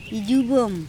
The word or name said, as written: ijubom